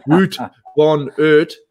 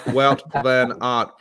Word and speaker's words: Wout van Aert: It's not Wout von Aert. But Wout van Aert, pronounced Wout van Aert.